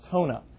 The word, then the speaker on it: Jona: One of our young men is originally from Uruguay, and his name's Jonathan, but we call him Hona, J-O-N-A, but it's pronounced Hona.